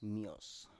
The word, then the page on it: mios: Biak